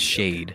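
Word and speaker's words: Shaed: So A-E-D, it's pronounced shade.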